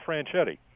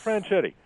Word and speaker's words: Franchitti: First off, you're not pronouncing his name right. Indy Bonics, it's Daryl Franchitti. Daryl Franchitti.